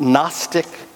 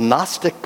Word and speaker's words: Gnostic: The words I have in mind are the Greek words Gnostic and Gnosticism. It's a silent G, which is why you need to put it up there so you can see what it is.